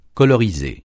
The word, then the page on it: coloriser: francais